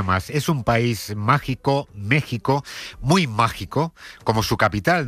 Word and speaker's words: México: Se escribe con X, pero se pronuncia con J, ese país que no existió hasta que llegaron los españoles, según Ayuso. – Mire, México no existió hasta que llegaron los españoles.